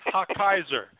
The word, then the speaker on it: Hochheiser: So let me say it. It's Hochgeiser. H-O-C-H-A-G-I-S-E-R.